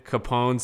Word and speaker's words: Capone: So it's Mike, period, Capone, C-A-P-O-N-E, dot R-A.